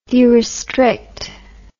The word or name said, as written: derestrict